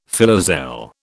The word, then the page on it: filoselle: British English